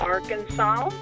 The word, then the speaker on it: Arkansas: She properly pronounces her home state for those traveling through. Go ahead. Okay. – This is McCrory, Arkansas.